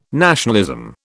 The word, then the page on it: nationalism: British English